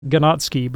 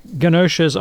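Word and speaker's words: Ganoczy: Gnatsky, but I think it's pronounced Ganache.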